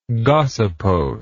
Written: gossypose

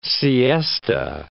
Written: siesta

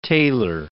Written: Taylor

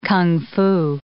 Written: kung fu

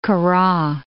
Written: Kura

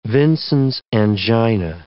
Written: Vincent's angina

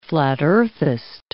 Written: flat-earthist

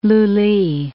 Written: Lully

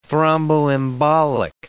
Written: thromboembolic

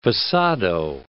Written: passado

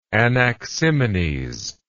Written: anaximenes

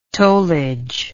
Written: tollage

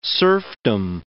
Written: serfdom

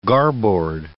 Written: garboard

